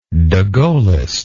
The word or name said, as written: degaullist